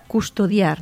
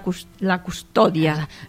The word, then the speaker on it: custodia: Per exemple, la paraula custodiar, no? Doncs custodia. No, exacte. – No es diu la custòdia. – Custodia, clar, és el verb conjugat, exacte.